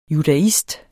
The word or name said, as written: judaist